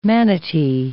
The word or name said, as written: manatee